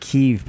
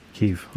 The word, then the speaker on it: Kyiv: If you're wondering why people are pronouncing it Kyiv, by the way, that's the Ukrainian way to pronounce. – Right. – So the Russian way is the other way.